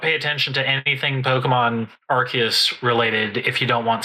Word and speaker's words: Arceus: I saw an actual pronunciation explanation of it, and it is Arceus.